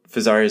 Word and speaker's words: Fazari: So Fazari is a brand. If you're not familiar with them, they're a direct-to-consumer company out of Utah, obviously Italian name. Obviously rhymes with an Italian sports car, which people like to mention a lot.